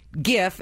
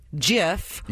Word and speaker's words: GIF: So many adults say GIF instead of JIF.